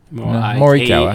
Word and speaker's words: Morikawa: It sounded like you were saying Morikawa. – Morikawa. – A-A-W-A. – Morikawa.